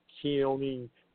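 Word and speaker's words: Koine: And that was a way that those, you know, the language of Christians back then was, I believe it's pronounced Kione Greek, and that was the symbol for Christ.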